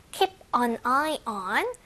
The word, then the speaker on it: keep an eye on: Keep an eye on. 요건 약간 발음이 조금 까다로워. Keep an eye on eye on. 이게 약간 on eye on 뭐지? 이럴 수 있는데 입에 많이 붙게끔 연습을 해주시고요. 계속 지켜보다라는 의미를 가지고 있고요. Keep A-I-N-G 하면 계속 A 하다라는 의미 가지고 있다는 거 기억하면서요.